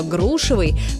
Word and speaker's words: грушевый: Но вернемся к грушам и запомним, что ударение во всех формах прилагательного «грушевый» падает на первый слог, как в слове «груша».